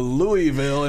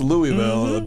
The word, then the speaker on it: Louisville: As soon as you go to Louisville and pronounce it wrong, you're in the South. Like, that's when you're corrected on how to pronounce Louisville mm-hmm.